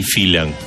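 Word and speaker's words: Phelan: Quiero aclarar que pronuncié mal un apellido cuando hablamos de las empresas que textiles cerraron. Las empresas de la espuma, yo pronuncié mal el nombre del espuma. Santiago Tati Filan. Uh-huh. Así se pronuncia.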